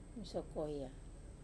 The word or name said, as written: masoi koya